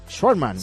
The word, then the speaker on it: Schwartzman: A ver si lo digo bien. Ya tenemos hora para el Nadal Schwartzman. – Sí, señor. Mejor dicho, imposible.